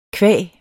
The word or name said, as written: qua